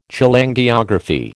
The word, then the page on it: Cholangiography: American English